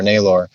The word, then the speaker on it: Nabor: your name's not Nabor with an E-R. – No. – It's Nabor.